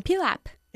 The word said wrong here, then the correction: pile up: ¿Y cómo se pronuncia? – Pile up. – A pile up. Pile como una pila, ¿ok? – Ella está esperando un niño.